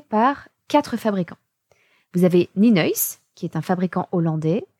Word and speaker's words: Nienhuis: Et on trouve aujourd'hui en France assez facilement le matériel Ninois. Alors Ninois, je vous l'épelle parce que ça ne se prononce pas du tout comme ça s'écrit. N-I-E-N-H-U-I-S.